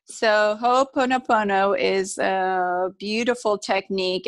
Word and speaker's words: Ho'oponopono: It's Ho'oponopono.